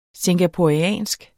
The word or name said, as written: singaporeansk